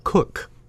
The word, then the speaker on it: Koch: It's spelled K-O-C-H. – Yeah. – We had neighbors once that it was spelled like that, and they pronounced it Cook. – Cook, yeah. – No, just Cook. like chef.